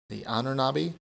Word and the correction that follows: Ahnenerbe: Is that how you say it? – The Ahnenerbe.